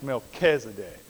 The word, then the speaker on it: Melchizedek: My wife says it's pronounced Melchizedek.